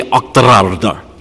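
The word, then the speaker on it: Auchterarder: And if you were Scottish, you would roll the R's, you would say Ochterarda. I can't even do it properly, right? Ochterarda. You got to roll your R's like that.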